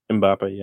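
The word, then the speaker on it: Mbappé: Mbappe, yeah.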